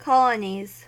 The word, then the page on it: colonies: American English